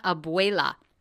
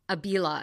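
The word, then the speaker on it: Abela: It's dropping May 10th for Back to Black, the biopic starring Marissa Abuela. Oh my God, am I saying no? Abila, I think. Abila, I think I'm saying it wrong. Abuela's your grandmother.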